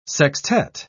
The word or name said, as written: sextet